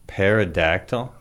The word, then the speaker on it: pterodactyl: It starts with a P. – Pterodactyl? – No, pterodactyl. – Pterodactyl. – It's a silent P.